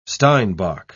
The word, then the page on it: steinbok: British English